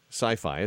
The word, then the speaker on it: Syfy: They spell it funny over there, S-Y-F-Y, Sci-Fi. It's just easy for you to remember that way.